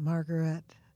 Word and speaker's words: Margaret: Oh, he has an accent on the last syllable. Margaret, are you grieving over Golden Grove on leaving?